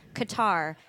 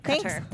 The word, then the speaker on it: Qatar: The Rabble podcast listeners are all over Texas, but also there are 19 in Qatar, which I always pronounce Qatar, and recently learned is incorrect. Qatar.